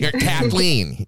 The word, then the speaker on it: Kathleen: And it's awesome to hear, you know, with Caitlin and the band, that now you have created music as well with them. – Kathleen. – Kathleen. Sorry.